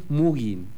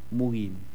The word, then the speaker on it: mogging: Este es Mugin. Se escribe M-O-G-G-I-N-G. Mugin.